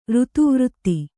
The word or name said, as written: ಋತುವೃತ್ತಿ